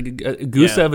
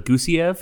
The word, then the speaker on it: Gusev: It was like Gusev is actually Gusiev.